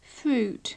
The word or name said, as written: fruit